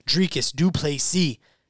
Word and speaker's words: Dricus du Plessis: So, the next fight that we are going to pick for, number 5, Derek Brunson versus number 10, Dricus du Plessis. Yes, the S is silent, remember. Derek Brunson comes into this fight off a second round knockout loss to Jared Cannonier 1 year ago.